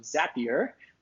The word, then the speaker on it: Zapier: Today I have with me Wade Foster, who is the founder, CEO of Zapier. I just learned is how it's pronounced. Zapier makes you happier.